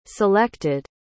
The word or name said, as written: selected